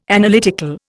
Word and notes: Analytical: Indian

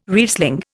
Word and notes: Riesling: Indian

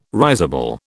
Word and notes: risible: British English

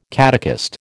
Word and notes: catechist: American English

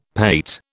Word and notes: pate: British English

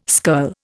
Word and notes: skirl: Indian

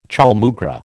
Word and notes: chaulmoogra: American English

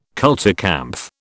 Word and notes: kulturkampf: British English

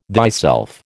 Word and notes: thyself: American English

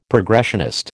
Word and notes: progressionist: American English

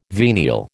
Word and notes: venial: American English

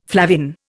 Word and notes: flavin: Indian

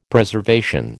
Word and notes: Preservation: American English

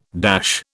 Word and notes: dash: British English

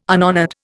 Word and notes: unhonoured: Indian

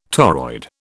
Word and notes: toroid: British English

